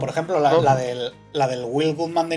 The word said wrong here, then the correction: Wild Gunman: de Nintendo era Wild Gunman, bien pronunciado.